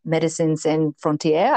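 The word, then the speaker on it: Médecins Sans Frontières: So like, I think a future bucket list will be for me to work with a company called MSF, or Medicines Sans Frontières, I think is how you pronounce it because it's French.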